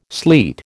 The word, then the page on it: sleet: American English